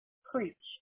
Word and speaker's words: Creech: Creech. C-R-E-E-C-H. – All right, perfect. And Kim, you spell that K-I-M, right? – Yes.